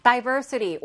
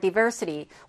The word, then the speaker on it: diversity: Diversity or diversity. 우리 교재는 지금 DI로 지금 되어 있는데, 여러분들 사전을 찾아보시면 DI, DAI라고 이렇게 발음 기호가 있는 것들도 꽤 됩니다. 그래서 diversity, diversity 둘다 발음은 가능하고요.